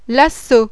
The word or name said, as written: lasso